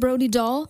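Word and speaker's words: Brody Dalle: Don't Mess With Me, ein ganz neues Stück von der aktuellen Platte von Brody Doll. Is it Brody Doll? – Yeah, you said it right.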